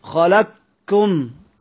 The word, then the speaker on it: خلقكم: مثال خلقكم، توفر شرط التحرك، توفر شرط الحركة قبل القاف، وتوفر شرط ميم الجمع، يبقى انطقها ازاي؟ خلقكم كاف واحدة مشددة، كاف واحدة مشددة، خلقكم، ما اقولش خلقكم قو، لا مش قاف، اما انطق الحرف الثاني، لان انا عندي الادغام هو النطق بالحرفين حرفا واحدا كالثاني مشددا كالثاني، يبقى هنا انطق ايه؟